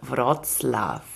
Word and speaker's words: Wrocław: Und zwar nach Breslau bzw. Wrocław. Richtig ausgesprochen? – Ja, also wenn wir jetzt keinen Protest von den polnischkundigen Hörerinnen haben, dann war es richtig.